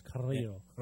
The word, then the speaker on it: Carrillo: Humberto Carrillo, I guess is how roll your R's, Carrillo.